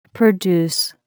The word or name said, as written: produce